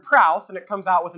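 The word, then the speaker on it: praus (πραΰς): The Greek term here is the term praus. Praus. If you spell it in English, it would be P-R-A-U-S.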